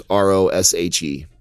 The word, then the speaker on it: Roshe: These are Nike Roche or Roush, however you want to pronounce it. It's Roshe.